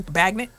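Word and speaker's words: Bagent: But Tyson, how did we say his name? – Bagnet. – Tyson Bagnet.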